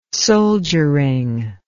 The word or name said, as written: soldiering